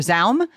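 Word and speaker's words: ZA/UM: I'm so excited to start talking about Disco Elysium, a role-playing game developed and published by, I think it's Z-A-U-M or Zaum. – It's apparently pronounced Zaum.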